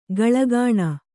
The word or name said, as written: ಕಾಳ್ಕೋಣ